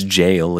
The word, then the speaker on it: gaol: Although in this case, jail is spelled G-A-O-L. If you've played Elden Ring, you've probably encountered that word and perhaps wondered, how the fuck do I pronounce this? It's just jail. It's just another spelling.